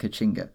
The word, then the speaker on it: Caatinga: So we're travelling to the Brazilian Caatinga, where, so when people think of, it's written Caatinga, but it's pronounced Caatinga.